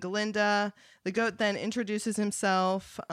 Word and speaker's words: Galinda: And this is important because in the movie and the stage production, we get Galinda saying in the classroom, like, oh, it's Galinda, like, you have to pronounce the ga, like, and she's being kind of bitchy about it.